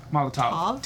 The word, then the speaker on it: Molotov: Is it Molotov or Toff? – Molotov. T-O-V. – V?